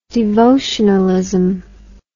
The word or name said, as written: devotionalist